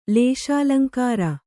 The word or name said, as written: ಲೇಶಾಲಂಕಾರ